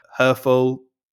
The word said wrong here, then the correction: Herfølge: Oh, Herfølge, but that's Hobie Kuhn now.